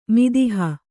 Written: ಮಿದಿಹ

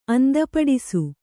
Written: ಅಂದಪಡಿಸು

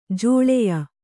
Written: ಜೋಳಯ

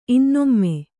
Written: ಇನ್ನೊಮ್ಮೆ

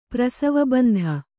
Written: ಪ್ರಸವಬಂಧ

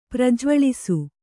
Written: ಪ್ರಜ್ವಳಿಸು

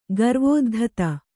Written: ಗರ್ವೋದ್ಧತ

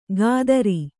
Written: ಖಾತ್ರಿ

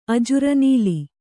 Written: ಅಜರನುಲಿ

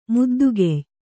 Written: ಮುದ್ದುಗೆಯ್

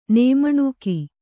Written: ನೇಮಣುಕಿ

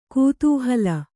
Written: ಕುತೂಹಲಿ

